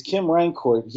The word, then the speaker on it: Kim Rancourt: because I don't know if this is the French pronunciation Rancour or if we Americanize this to Kim Rancourt. – It's Kim Rancourt.